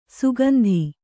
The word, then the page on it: ಸುಗಂಧೆ: sugandhe; Kannada